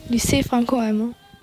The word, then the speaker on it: Lycée Franco-Allemand: Lycée, jetzt sag mal, wie spricht man das richtig? – Lycée Franco-Allemand.